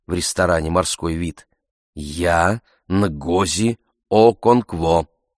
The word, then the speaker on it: Нгози: «Нгози. Н. Носовое».